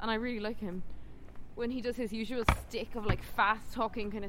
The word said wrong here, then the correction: shtick: It's not pronounced stick, it's pronounced shtick.